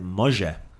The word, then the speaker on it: može: You pronounce it može. – Može.